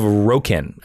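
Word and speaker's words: Rokyn: And he'd come to fulfill his promise, helping the Kandorian survivors settle on the planet of Rokin. I think that's how you say it. It's R-O-K-Y-N.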